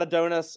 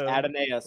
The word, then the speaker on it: Adonais: It's not Adonis. It's not. – I know. – Y'all say it right. – I know. – It's Adonais.